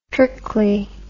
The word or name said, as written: trickly